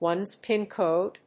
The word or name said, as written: one's PIN code